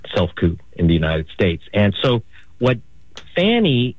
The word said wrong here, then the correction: Fani Willis: Fani, I should say. I've been mispronouncing her name, and I do apologize. It's Fani Willis.